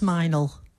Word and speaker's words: Mildenhall: Honestly, it's spelt Mildenhall. One word, Milden. Very long word, Mildenhall. Pronounced Minel. What's that about?